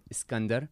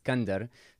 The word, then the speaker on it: Skander: So when I use Arabic alphabet, I pronounce my name Skander. And when people pronounce my name, they pronounce it Skander. So without the A. When I came to Turkey and I say my name is Skander, some people would like to mess with me and say Sikander.